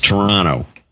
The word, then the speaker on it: Toronto: And you don't pronounce the second T in Toronto. It's Toronto.